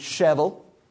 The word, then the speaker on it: Shelbyville: And I don't know how familiar any of you are with Shelbyville, but right there in the square in Shelbyville, which, by the way, just the way I'm pronouncing Shelbyville proves that I'm not from Shelbyville. Yeah, it's Shevel. Shevel.